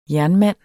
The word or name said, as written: jernmand